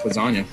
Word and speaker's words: lasagna: I think Miles took the approach of, real G's moving silence like lasagna. – Real G's moving silence like lasagna. Oh, I get it. Because the G in lasagna is silent.